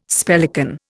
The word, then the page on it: spellican: Indian